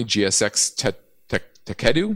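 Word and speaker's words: Techedu: Citron Research, they just came out with a new one on a Chinese listed online education company, GSX Takedu, is how you say it.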